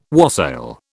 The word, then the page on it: wassail: British English